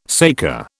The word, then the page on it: saker: British English